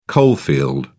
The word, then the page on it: coalfield: British English